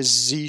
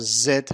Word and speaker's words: Z: زی تلفظ امریکایی هست. خانمی که داشت حروف رو میخوند امریکایی بود، بنابراین این حرف رو زی تلفظ کرد. تلفظ زد هم درسته. تقریبا بقیه کشورهای انگلیس زبان به جز امریکا همه این رو زد تلفظ میکنن. فقط امریکایی ها هستن که زی تلفظ میکنن.